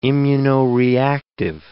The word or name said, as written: immunoreactive